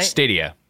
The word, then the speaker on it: Stadia: Stadia.